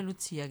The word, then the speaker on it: Lucia: Ich glaube, dass die Betreiber Lucia sagen. – Das sagen wir Lucia, genau. – Quasi, wie sagt man, romanisch-deutsch ausgesprochen.